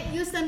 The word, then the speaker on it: Houston: Bacanya Houston we have a problem.